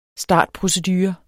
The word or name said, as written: startprocedure